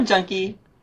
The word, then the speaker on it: Juhnke: How do you say the last name for exactly? – Yeah, Juhnke. Yeah. – Juhnke. Yeah, it's Juhnke. Liz Juhnke. – You got it. – Juhnke. The H is silent.